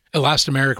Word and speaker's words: elastomeric: Elastomeric resin.